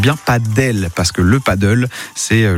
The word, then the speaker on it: padel: Tout le monde, enfin certains disent paddle, d'autres padel.